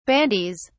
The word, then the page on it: bandies: British English